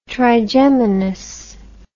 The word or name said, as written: trigeminus